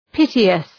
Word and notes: piteous: ‘pıtıəs

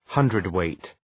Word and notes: hundredweight: ‘hʌndrıd,weıt

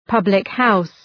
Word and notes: public house: {,pʌblık'haʋs}

